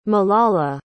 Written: malala

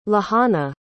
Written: lehana

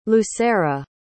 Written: lucera

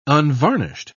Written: unvarnished

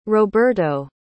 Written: roberto

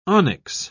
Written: onyx

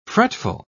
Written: fretful

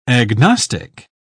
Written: agnostic

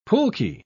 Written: pulque

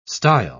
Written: stile

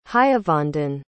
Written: hayavandan

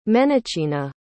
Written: menichina

